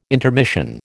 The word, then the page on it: intermission: American English